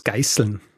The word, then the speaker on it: Geißel: Ich glaube, das Problem ist äh, die Aussprache des EIs am Anfang, ne? weil, glaube ich, dialektal sagen wir eher, G, also wir gehen so drüber hinweg und deshalb wirkt das S so härter dann. Naja, ah wahrscheinlich. Wenn du Geisel also, sagst, Geißel.